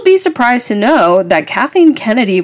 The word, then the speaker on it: Kathleen Kennedy: I do know that we can thank Kathleen Kennedy.